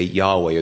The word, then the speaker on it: Yahweh: And if you put some vowels in there, we say Yahweh, or that's how we pronounce it.